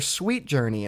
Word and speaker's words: Suite Journey: But they're Sweet Journey, and that's spelled S-U-I-T-E.